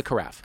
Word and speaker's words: carafe: Is it carafe or carafe? – Carafe. – Carafe, okay. – Yeah. – I said it wrong both ways.